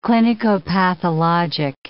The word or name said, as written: clinicopathologic